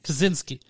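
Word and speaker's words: Kosinski: Kaczynski.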